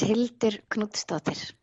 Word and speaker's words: Hildur Knútsdóttir: So now, Hildur, how about you tell everyone how you actually say your name? – It's Hildur Knútsdóttir. – That sounds so much better.